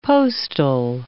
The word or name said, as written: postal